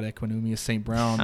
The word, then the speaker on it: Equanimeous St. Brown: I cannot believe you pronounced his name so correctly just then.